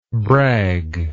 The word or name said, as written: bragless